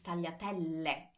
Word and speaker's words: tagliatelle: With this one, pay attention to the ending E. As always in Italian, it's E, so it's tagliatelle. It's not tagliatelle or tagliatelli. We do pronounce all our vowels, and we pronounce the E always as E.